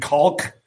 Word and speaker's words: caulk: The hardest, trying to pronounce the L in caulk when you're at Home Depot and you're asking the guy, I need some caulk. You will never try so hard in your life to pronounce that L in there.